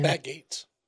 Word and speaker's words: Gaetz: So if, what was it, how do you say his name? – Matt Gaetz.